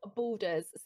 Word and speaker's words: Baldur's: I even like today, like Baldur's Gate. Have you ever heard of that? – No, I've heard of Borderlands, but I've never Borders Gate. – Baldur's, so like BAL.